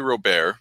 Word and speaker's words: Robert: He says Luis Robert, but like it's Louis Robert. Like it's a French, like a French colonized name.